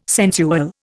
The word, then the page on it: sensual: Indian